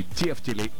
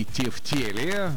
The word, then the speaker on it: тефтели: На самом деле, вы не поверите, но два варианта ответа считаются верными. Можно говорить и тефтели, и тефтели. Есть, конечно, справочник «Русское словесное ударение» имени Зарбы. Он рекомендует ударение на второй слог в тефтели.